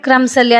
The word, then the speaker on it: crumbs: ಕ್ರಮ್ಸ್, ಹೆಚ್ಚಾಗಿ ಬಳಸಿರ್ತೀರ bread ಕ್ರಮ್ಸಲ್ಲಿ, ಅದು ಏನೋ ಅಡುಗೆ ಮಾಡಿದೆ ಅಂತ. ಕ್ರಮ್ಸ್ ಬರೀ CRUMBS ಅದು, ಆದರೆ ನಾವು ಹೇಳೋದು ಕ್ರಮ್ಸ್ ಅಂತ, ಬಿ ಉಚ್ಚಾರ ಮಾಡೋದೇ ಇಲ್ಲ.